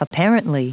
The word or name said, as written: apparently